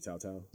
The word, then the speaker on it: Tautou: But uh, another great, uh, I think it's Tautou. Is how you say Audrey. Audrey Tautou.